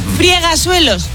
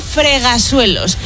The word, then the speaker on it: fregasuelos: Friegasuelos, no se dice. Se dice fregasuelos.